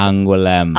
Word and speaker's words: Angoulême: Kind of like how you were transatlantic in Angoulême. – That was actually really close to how it's pronounced. It's Angoulême.